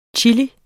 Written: CHILIER

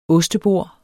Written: OSTEBORDE

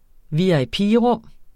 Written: VIP

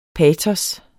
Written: patos

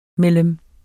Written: mellem

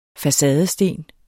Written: facadesten

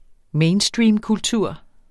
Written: mainstreamkultur

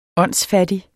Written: åndsfattig

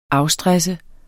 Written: afstresse

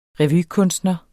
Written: revykunstner

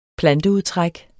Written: planteudtræk

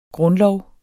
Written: grundlov

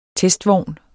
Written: testvogn

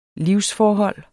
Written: livsforhold

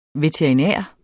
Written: veterinær

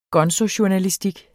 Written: gonzojournalistik

